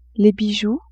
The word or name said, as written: bijoux